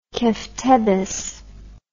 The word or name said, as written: keftedes